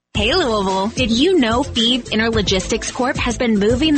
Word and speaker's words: Fives: Thieve is spelled F-I-V-E-S, like the number, but pronounced Thieve.